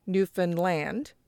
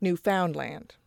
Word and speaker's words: Newfoundland: So one of them wanted to put emphasis upon the land, like Newfoundland, and the other was adamant that the found was what was stressed, like Newfoundland.